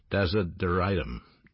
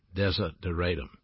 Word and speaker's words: desideratum: The last time I was with you, we talked about the divine, and I pronounced the word for you, desideratum, desideratum.